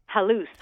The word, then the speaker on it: halus: One of your sources referred to Barack Obama as, I hopefully am not mispronouncing this, as halus? – Halus.